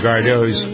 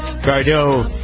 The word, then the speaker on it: Gardot: Is it Gardot or Gardot? – I believe it would be, like Margot Robbie.